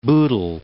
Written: Bootle

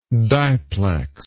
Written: diplexer

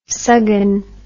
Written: suggin

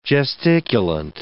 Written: gesticulant

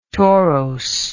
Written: torose

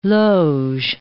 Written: loge

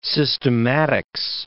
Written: systematics

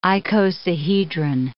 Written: icosahedron

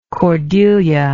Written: cordelia

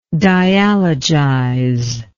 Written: dialogize

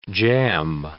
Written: jamb

